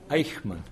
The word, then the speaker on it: Eichmann: Eichmann. – Eichmann, forgive Eichmann. Me. I'm sorry, Eichmann. – E-I-C-H. – E-I-C-H.